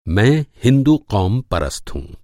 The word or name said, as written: क़ौम-परस्त